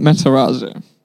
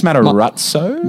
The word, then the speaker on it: Matarazzo: I think it's Matarazzo. – Ratso. – Sort of like gotcha, pizza. Gotcha. You know, he's got like the double Z, I believe, in Italian is the...